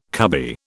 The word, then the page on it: cubby: British English